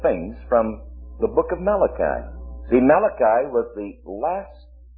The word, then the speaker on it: Malachi: So it is sent then, it's signified by Malach-E, or as we pronounce it, Malachi. Malachi.